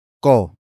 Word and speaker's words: ko: Word for word it's, ipinagguhit ko ang guro ng mansanas. Ipinagguhit ko ang guro ng mansanas.